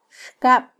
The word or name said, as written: xkab